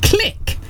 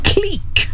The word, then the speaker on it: Clique: We do not pronounce it click. It's pronounced cleek, for goodness sake.